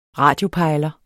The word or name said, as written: radiopejler